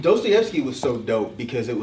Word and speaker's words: Dostoevsky: Even though I love Black Dot, but he mispronounces his name. I thought it was funny. I think he called like Dostoevsky. It's Dostoevsky.